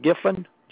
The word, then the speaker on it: Giffin: Donald Giffen, G-I-F-F-I-N. – All right, thank you. Give me 1 second, Mr. Griffith. – No R in it. – No R. Thank you.